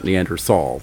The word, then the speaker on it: Neanderthal: You talk to most paleoanthropologists today, and you look at most of the literature today, and everybody has gone back to Neanderthal, the T-H-A-L spelling, and most pronounce it Neanderthal these days.